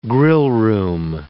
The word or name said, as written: grillroom